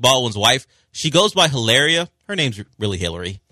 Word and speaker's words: Hilaria: Her name would be Hilaria because you don't pronounce the H.